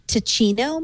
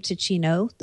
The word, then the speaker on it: Ticino: It's Ticino, maybe? T-I-C. I-N-O. – I think if it's Italian, it would be Ticino.